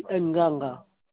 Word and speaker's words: Nganga: Mon nom, c'est Nganga Sylvestre.